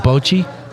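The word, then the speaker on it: Bocce: You can go to Milo's across the street from their office, play a game of Bochi, and it's pronounced Bochi. It's not Bochi.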